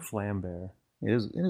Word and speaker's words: Vlambeer: Vlam is pronounced close to flam, and beer is pronounced bear. So it's pronounced flam bear, which is easy to remember since it means flame bear.